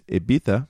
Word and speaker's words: Ibiza: Me and Angie, we actually met each other halfway around the world on the tiny party island, pronounced in America as Ibiza. To the Europeans, it is Ibiza.